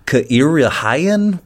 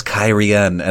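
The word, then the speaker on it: Cairhien: And I was just having a long conversation with him the other day, and he was like, Kairihyen? What the hell, man? And I'm like, oh no, it's Kairien.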